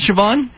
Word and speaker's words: Siobhan: Is it Siobhan? Is that how you pronounce it, Siobhan? – Yes, it is.